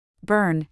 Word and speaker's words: BHRN: To coordinate services, Measure 110 required grantees to form a Behavioral Health Resource Network, or BHRN, pronounced BURN, in each county.